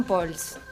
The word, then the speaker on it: Pauls: No, pero pasa, viste que después me arrepentí, tendría que haber dicho Pols. – Pols de entrada. – Sí, o sea, pero es Pauls. – Porque el pueblo, ¿no?, te eligió como Gastón Pols.